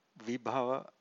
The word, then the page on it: vibhava: Pali